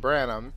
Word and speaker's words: Branum: It's just Branum.